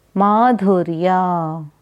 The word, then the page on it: Madhuryaa: Sanskrit